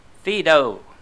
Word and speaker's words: Phaedo: Plato's work is called Phaedo. That's spelled P-H-A-E-D-O. Phaedo, P-H-A-E-D-O, is a dialogue which depicts the death of Socrates.